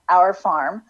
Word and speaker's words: Auer: It's spelled A-U-E-R, Our Farm, but it's really O-U-R.